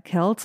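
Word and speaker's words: Kelce: Das haben wir nicht getan, außer noch einmal richtig zu stellen, dass er Kells ausgesprochen wird.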